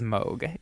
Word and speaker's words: Moog: It looks like it's spelled Moog, but it is Moog. Yeah, like Vogue.